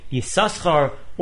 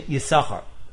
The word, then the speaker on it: Yisachar: However, the Chasam Sofer in Parshas Vayetze says that the first time Yisachar's name is written in the Torah, it should be pronounced with a double shin Yisachar, and after that, with one shin Yisachar.